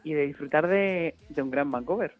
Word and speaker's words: Vancouver: Abonnés se llama Vancouver, ¿o cómo lo dices tú? ¿Cómo lo pronuncias? – Así mismo es, Vancouver.